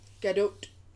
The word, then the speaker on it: Gadot: Gadot. – You do pronounce the T, which seems surprising, but pronounce the T.